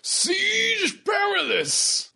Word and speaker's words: Siege Perilous: Siege Perilous. Rhymes with garrulous.